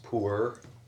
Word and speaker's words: pu'er: But pu'er is awesome. And I think it's actually pronounced poor, by the real tea.